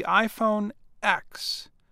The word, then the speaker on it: iPhone X: After a short music video, Cook presented the iPhone X, and he pronounced the X as the number 10.